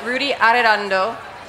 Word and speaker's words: Rudy Arredondo: And Rudy Arredondo from the National Latino Farmers and Ranchers Trade Association.